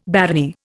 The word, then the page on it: barony: Indian